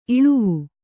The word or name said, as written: ಇಲ್ಲವೆ